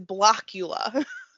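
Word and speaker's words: Blåkulla: And I actually just want everyone to know, I am saying that correctly. It is Blockula.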